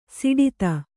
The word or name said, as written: ಸೋಡ್ತಿ